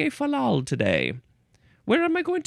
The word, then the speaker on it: fal-lal: Next word is falal or falal. Falal. You could emphasize either syllable. It's fine. F-A-L-L-A-L, but there is a hyphen between the middle L's.